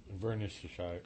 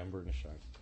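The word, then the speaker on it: Inverness-shire: Is it Invernessshire? – Invernessshire, yeah, Invernessshire.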